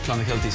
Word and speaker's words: Clonakilty: Irish tea bags are Clonakilty sausages from Cork. – Clonakilty.